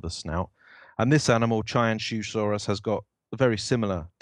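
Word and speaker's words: Qianzhousaurus: This is called, it's spelt, if I was a dumb English person, I would say it Kyanzhusaurus, but apparently it's pronounced Chianzhusaurus sinensis, and it's an Allioramus-like tyrannosaurid published by Juncheng Liu and colleagues in Nature Communications, brand new paper.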